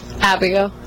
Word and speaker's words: Abigail: Abigail.